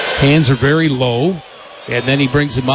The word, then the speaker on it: Lowe: spells his name L-O-W-E, pronounces it Low.